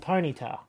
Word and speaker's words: Ponyta: it's a Gen 1 Pokemon, and it's a pony, and it's called Ponyta. – Panita. – And Jackson called it Panita.